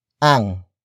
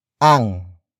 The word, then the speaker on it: ang: Ang. Ang. Ang. Ang is spelled as A-N-G.